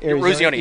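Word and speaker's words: Eruzione: or maybe it's like a 22 by 28, there's always the classic one of, there's Mike Arizioni, is that how you say it? – Arizioni, yep. – Arizioni, and Jim Craig, who's the goalie.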